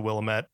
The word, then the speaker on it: Willamette: Fun fact about Willamette. They pronounce it Willamette in the game. Everyone calls it the Willamette Mall.